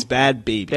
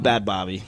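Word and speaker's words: Bhad Bhabie: it's not Bad Bobby, like Bobby Hill from King of the Hill. It's baby, like a infant human being.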